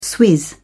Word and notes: swizz: British English

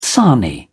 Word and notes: sarnie: British English

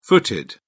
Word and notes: footed: British English

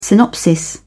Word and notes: synopsis: British English

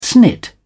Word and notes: snit: British English

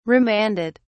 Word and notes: remanded: British English

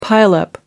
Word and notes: pile up: American English

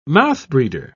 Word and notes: mouthbreeder: British English

